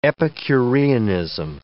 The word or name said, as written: epicureanism